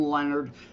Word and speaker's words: Lenard: Lenard, again, looks like Lenard, it's yep.